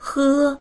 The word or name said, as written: he1